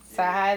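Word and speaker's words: CyHi: Is it Sai the Prince? – Sai High the Prince.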